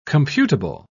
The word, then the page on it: computable: British English